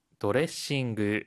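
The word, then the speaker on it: ドレッシング: 드레싱이라고 할때 중간에 초금이 있고 한국어와 모음도 다르니 발음 연습 많이 해주시고요.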